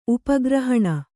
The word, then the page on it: ಉಪಗ್ರಹಣ: upa grahaṇa